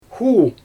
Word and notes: xuu: Xyzyl

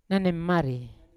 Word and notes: nanimari: Yawa

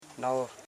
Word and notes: navo: na.vo; Koro

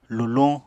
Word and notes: luluñ: Apatani

